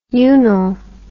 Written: unnil

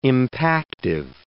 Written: impactive

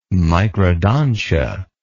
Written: microdontia

